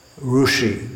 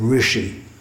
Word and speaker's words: Rishi: Rushi, they'll say, for instance, instead of Rishi.